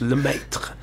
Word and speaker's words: Lemaitre: It's French, le maître.